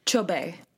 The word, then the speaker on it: Chobe: And I was wondering if you could talk about your focus specifically on the, and pardon my pronunciation, is it the Chobe National Park? – Chobe.